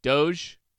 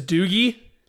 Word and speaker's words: Doge: D-O-G-E, Dogecoin? Doge?